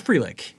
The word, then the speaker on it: Frelick: It's Frelick.